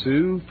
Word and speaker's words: Hsu: H-S-U, Paul Sue from Cool.